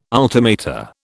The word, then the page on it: ultimata: British English